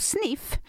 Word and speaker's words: Sniph: Och Sniff, som den här då, eh, underbara sponsorn heter, det är alltså uttalat Sniff men, men det är eh, S-N-I-P-H kan vara intressant att veta.